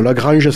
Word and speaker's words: La Granja: Alors, La Grangea, ça s'écrit La Grangea. L-A-G-R-A-N-J-A. Voilà, parce qu'on dit en occitan, le A final, c'est O. Donc, Grangea.